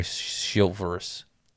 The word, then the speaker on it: chivalrous: We've not been very chivalrous. I'm not saying it right still. – You did good. Yeah, that was actually really good. That's better than I could have said it.